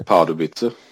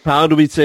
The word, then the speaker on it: Pardubice: Pardus eller något sånt där heter. – Pardubice. – Pardubice heter det, ja. Tack för min dåliga uttal. Att du räddade mig. Pardubice.